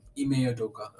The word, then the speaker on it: Ime Udoka: Ime Udoka. – Udoka. Ime Udoka is my coach of the year.